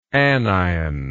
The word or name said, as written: anionically